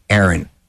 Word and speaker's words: Airn: No, and they spelled it A-I-R-N, Aaron. – Aaron. – Like Air Jordan, but Aaron.